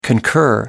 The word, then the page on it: concur: American English